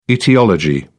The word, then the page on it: aetiology: English; British English